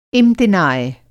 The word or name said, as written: इम्तिनाय